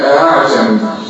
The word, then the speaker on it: أعجمي: طيب الألف المسهلة في كلمة واحدة في القرآن كله اللي هي أعجمي، أعجمي ماشي. أعجمي هكذا، أعجمي أعجمي أعجمي أعجمي شد أعجمي لا أعجمي أعجمي هكذا ينطق بالهمزة بين الألف والهمزة.